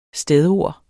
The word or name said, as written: stedord